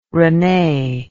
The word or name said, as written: renee